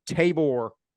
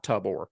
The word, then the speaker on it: Tabor: Also, we've been pronouncing Tabor Tyson's name wrong. We got a DM about that. I think it's Tabor, not Tubor.